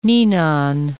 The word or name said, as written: ninon